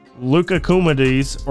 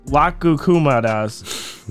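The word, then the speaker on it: loukoumades: They're also warded off by singing a song and throwing leucocumides or soup donuts or syrup donuts. – That's exactly how you pronounce that. – Lacumadas. – Yeah, do it again. That's good. – Lacumadas.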